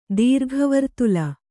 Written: ದೀರ್ಘವರ್ತುಲ

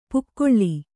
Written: ಪಕಳಿ

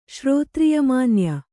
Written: ಶ್ರೋತ್ರಿಯಮಾನ್ಯ

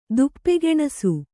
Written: ತುಪ್ಪಗೆಣಸು

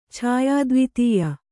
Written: ಛಾಯಾದ್ವಿತೀಯ

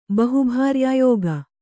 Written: ಬಹುಭಾರ್ಯಾಯೋಗ

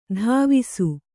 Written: ಧಾವಿಸು

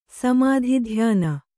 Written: ಸಮಾಧಿಧ್ಯಾನ

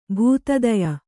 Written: ಭೂತದಯ

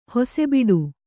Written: ಹೊಸೆಬಿಡು

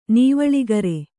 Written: ನವಿಲುಗರಿ

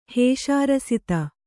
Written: ಹೇಷಾರಸಿತ

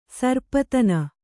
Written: ಸರ್ಪತನ

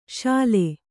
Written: ಶಲೆ

